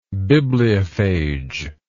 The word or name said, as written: bibliophage